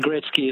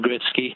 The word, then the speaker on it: Gretzky: Wayne Gretzky has always been pronounced Wayne Gretzky, and I suspect Michael just wasn't familiar with how to pronounce it when he read it off the whatever he was reading off.